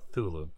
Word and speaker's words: Cthulhu: It's more of a sound. It's just a sound. It's Cthulhu. – Oh, Cthulhu. Okay. – Cthulhu.